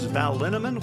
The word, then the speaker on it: Linneman: Val Linneman, which rhymes with cinnamon,